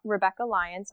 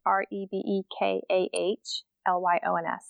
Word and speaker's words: Rebekah Lyons: It's Rebekah Lyons. So it's Rebekah Lyons.